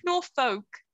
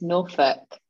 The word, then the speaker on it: Norfolk: It's not like Norfolk. – It's Norfolk. – Yeah, but it's F O L K folk, not fuck.